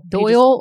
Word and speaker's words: Doyle: She rarely comes down, and her husband Dole. – Doyle. Just, what is Dole, his name? Dole. Can you spell that? – D-O-Y-L-E. Doyle. Everyone, nope, everyone call him Doyle.